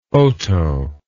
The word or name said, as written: oto